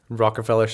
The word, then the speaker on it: Rockefeller: Did I say that right? – Nope, I don't think you did. – Rockefeller? No, you got it.